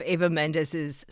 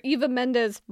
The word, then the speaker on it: Eva Mendes: I have been calling her Eva Mendes my entire life. No, but this NBC story is calling her Eva Mendes, which clearly is how she must want her name pronounced, because it's an official press piece about the sponge line.